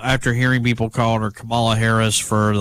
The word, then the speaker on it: Kamala: Ron writes this, just an FYI, I read an article online from Harris California Days, and she said the correct pronunciation of her name is Kamala. Punctuation mark, comma, and then lock, Kamala.